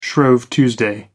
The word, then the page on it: shrove tuesday: American English